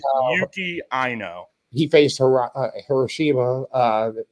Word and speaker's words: Ino: I think it's just Eno, I N O, or Yuki Ino. It's Ino.